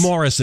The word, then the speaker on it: Maurice: But yeah, that's Maurice. – Yeah. – Maurice. – That's Morris. In the UK, it's pronounced Morris.